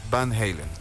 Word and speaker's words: Van Halen: Un 2020 fallecía Eddie Van Halen, un virtuoso guitarrista y cofundador de la gran banda Van Halen. No sé si lo estoy pronunciando bien. ¿Está bien? – Está bien. Eddie perfecto. Van Halen. – Van Halen, perfecto.